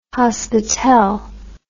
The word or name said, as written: hospitel